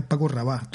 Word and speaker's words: Paco Rabal: Paco Rabal, ¿no? Con acento en la... – Todo seguido. Con la L sin pronunciar. La L líquida.